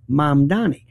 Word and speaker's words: Mamdani: It's Mom, like Mom and Dad, Donnie, like Donnie Osmond, Mamdani.